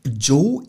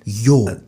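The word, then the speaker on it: yo: Also, Joe ist nicht Joe, das erst einmal, ja.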